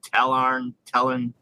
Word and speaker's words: Tallarn: But he had the video was on Talarn Desert Raiders, and I probably said that wrong. Talarn, it's T-A-L-L-A-R-N, so Talarn.